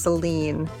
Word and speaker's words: Selene: And let's see, you said the Greek goddess was Selena. I think it's Selene.